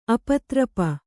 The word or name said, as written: ಆಪಾತರೂಪ